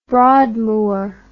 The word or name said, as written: Broadmoor